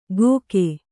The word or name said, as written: ಗೋಕೆ